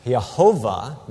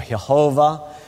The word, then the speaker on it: Jehovah: You could also pronounce it Jehovah, not really Jehovah. There's not a hard J sound in Hebrew or in Latin for that matter.